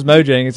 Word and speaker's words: Mojang: I thought you said like Mohangi or whatever. – Because I'm just like, I was like, that's probably the most different pronunciation I've ever heard. – I knew it was Mojang,